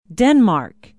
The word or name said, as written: denmark